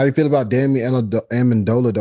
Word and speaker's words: Amendola: Danny, Danny Amendola. – Amendola.